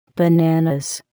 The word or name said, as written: bananas